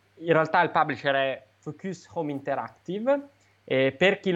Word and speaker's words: Focus: senti come lo dico con la pronuncia francese, essendo loro francesi. Ieri mi hanno cazziato, allora oh, Focus, Focus.